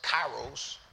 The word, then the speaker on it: kairos: And I go into his timing, which in the Greek is pronounced kairos.